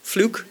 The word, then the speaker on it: Fluke: Fluke? I said it correctly. You've got to be careful with Irish people because they so easily can say a different F word.